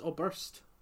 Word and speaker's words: Oberst: Salutations by Conor Obrest. Oberst? Is it Oberst or Obrest? – Obrest. – Because you were saying Obrest, but it's Oberst. That's the way it's spelled.